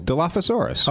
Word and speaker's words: Dilophosaurus: Oh, Dilophosaurus. – All right, so it was he was eaten by a Dilophosaurus. With an F? – P-H. – Oh, okay, so Dilophosaurus.